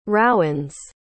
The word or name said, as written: rowens